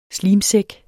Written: slimsæk

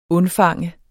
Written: undfange

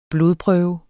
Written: blodprøve

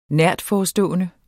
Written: nærtforestående

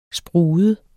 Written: sprude

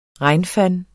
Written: regnfang

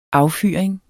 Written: Affyring

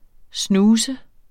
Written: snooze

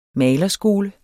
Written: malerskole

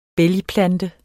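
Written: BÆLGPLANTER

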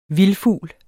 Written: vildfugl

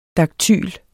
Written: daktyl

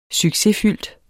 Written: succesfyldt

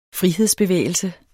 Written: frihedsbevægelse

